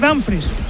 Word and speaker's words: Dumfries: Lo digo bien, Dumfries. – Tengo dudas en esa ¿cómo es exactamente? – Yo creo que es Dumfries.